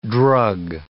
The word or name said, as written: drug